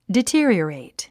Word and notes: deteriorate: dití ə riərèit; ディティアリアレイト

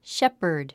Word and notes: shepherd: ʃépərd; シェパァド

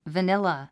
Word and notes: vanilla: vənílə; バニラ

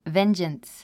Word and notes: vengeance: véndʒəns; ベンジャンス

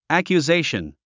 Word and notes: accusation: æ`kjuzéiʃən; アキュゼイション

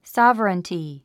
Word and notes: sovereignty: sɑ’vərənti; サァバランティ

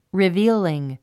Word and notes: revealing: rivíːliŋ; リビーリン

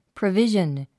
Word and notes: provision: prəvíʒən; プロビジョン

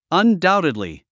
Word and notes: undoubtedly: əndáutidli; アンダウテドリィ

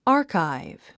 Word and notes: archive: άːrkaiv; アーカイブ

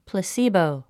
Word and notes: placebo: pləsíːbou; プラスィボォ

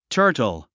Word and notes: turtle: tə́ːrtl; タートォ

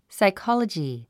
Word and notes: psychology: saikάlədʒi; サイコォロジィ

